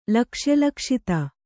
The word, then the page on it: ಲಕ್ಷ್ಯಲಕ್ಷಿತೆ: lakṣya lakśite